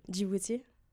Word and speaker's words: Djibouti: Djibouti.